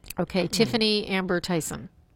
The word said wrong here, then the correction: Thiessen: Tiffany Amber Thiessen. – Thiessen, is that how you say it?